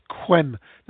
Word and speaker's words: Yquem: I suppose the most famous one there, the ultimate dessert wine, is Quem. That's spelled Y-Q-U-E-M.